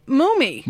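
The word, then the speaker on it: Mumy: He pronounces the name Mummy.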